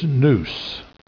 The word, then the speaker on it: nous: The Greek word here is nous. We would spell it N-O-U-S.